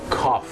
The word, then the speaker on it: cough: คุณผู้ชมครับภาษาอังกฤษเป็นภาษาที่บางครั้งเนี่ยนะครับการออกเสียงไม่แมทกับการสะกดอย่างแรงนะครับเช่นคำว่าอาการไอหรือมีการอาการไอนั่นเองครับ C O U G H ออกเสียงว่า cough c